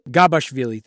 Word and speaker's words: Gabashvili: First round, he plays Temuraz Gashvili. Hope I pronounced that correctly. – Gabashvili, thank you.